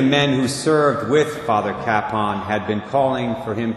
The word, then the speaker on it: Kapaun: Father Emil Kapaun, that's spelled K-A-P-A-U-N,